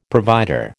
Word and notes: provider: American English

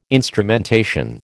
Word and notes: instrumentation: American English

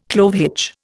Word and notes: clove hitch: Indian

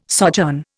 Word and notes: sojourn: Indian

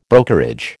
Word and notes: brokerage: American English

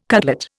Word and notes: cutlet: Indian

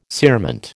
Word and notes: cerement: American English

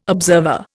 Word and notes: observer: Indian

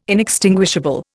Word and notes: inextinguishable: Indian

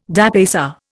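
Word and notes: dépaysé: Indian

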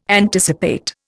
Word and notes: anticipate: Indian

